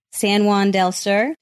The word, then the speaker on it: San Juan del Sur: San Juan del Sur.